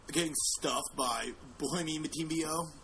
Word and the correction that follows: Bismack Biyombo: I think it's pronounced Bismack Biyombo. – Yeah, that's close enough.